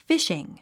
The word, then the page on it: fishing: fíʃiŋ; フィッシング